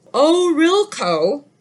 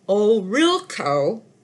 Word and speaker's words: Ohorilko: Orilko, his name is spelled O-H-O-R-I-L-K-O, Orilko, from